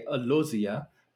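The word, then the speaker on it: Alozie: Real quick, but since we were talking about Houston, I wanted to bring up Michelle, as you mentioned, and I'm going to say Alozia because that is what she said.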